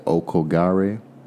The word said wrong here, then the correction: Aokigahara: Aokigahara. – Aokigahara, the Sea of Trees.